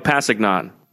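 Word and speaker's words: Kpassagnon: Tano Kapasignan. Did I get that right? – Yes. What's he in high school? – Well, the K, I believe, is silent. – Tano Pasignan.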